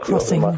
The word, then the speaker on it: Xing: Ja, sicher, aber äh, also ich finde ein ganz, ein ganz schönes Beispiel ist ja Xing zum Beispiel oder oder äh, wie spricht man es jetzt aus? – Crossing. Crossing. – ja.